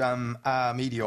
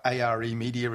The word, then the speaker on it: Are Media: But in addition to R Media, or ARE Media, as some people call it, it's spelled ARE,